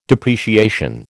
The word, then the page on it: depreciation: American English